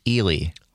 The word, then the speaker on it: Ely: I will say, though, Phil in Minneapolis just called in and says it is pronounced Ely. – Oh, really? Eli. Well, see, immediately, that's another reason the people in Ely don't want me there is because I can't pronounce their town name. Who wants this Wisconsin boy on our airwaves? – Mark in Prairie du Sac also texted in with that pronunciation. – Well, I appreciate that. That's the first correct pronunciation I've heard.